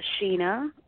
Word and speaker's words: Sheena: Uh, Sheena. – I... Sheena. Okay, like Sheena E.